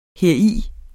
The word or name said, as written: heri